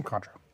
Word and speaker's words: Cointreau: Let's do some Contra. – See, according to YouTube, I thought it's spelled with a Q, like Cointreau. – Yeah, Cointreau.